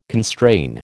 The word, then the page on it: constrain: American English